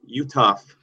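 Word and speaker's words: Uthoff: It's Jared Utoff. You don't say the H in his last name. And his Jared is spelled J-A-R-R-O-D.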